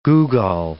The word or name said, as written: googol